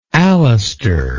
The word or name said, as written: Alastair